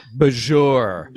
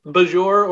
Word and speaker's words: Bajor: We have yet a new third pronunciation of Bajor. – Oh, yes. – Yeah, not Bajor or Bajor, but Bajor. – Bajor. – It's about the emphasis.